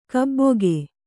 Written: ಗಬ್ಬಿಗೆ

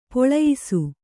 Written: ಪೆಲ್ಲಯಿಸು

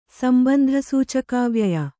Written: ಸಂಬಂಧಸೂಚಕಾವ್ಯಯ

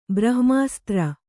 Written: ಬ್ರಹ್ಮಸೂತ್ರ

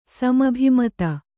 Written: ಸಮಭಿಮತ

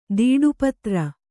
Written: ದೀಡುಪತ್ರ

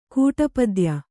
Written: ಕೂಟಪದ್ಯ

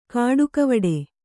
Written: ಕಾಡಿಗೆವಿಡಿ

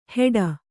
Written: ಹೆಡ

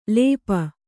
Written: ಲೇಪು

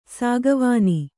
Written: ಸಾಗುವಾನಿ

